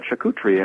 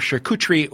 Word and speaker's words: charcuterie: There's as many pronunciations for charcuterie or charcuterie, as I say it, as there are for sommelier.